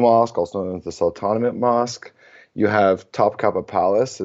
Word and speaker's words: Sultanahmet: Firstly, to start off, I think everyone needs to be looking at the Sultanahmet neighborhood. That's mm-hmm. how you say it colloquially, but it's named after the Sultan Ahmet. So it's literally Sultan Ahmet.